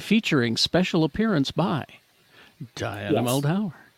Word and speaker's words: Muldaur: Imagine being a fan of the original series and seeing Diana Muldaur. I cannot remember how to pronounce her name. Diana Muldaur? – Yeah. – It's Muldaur. Yeah.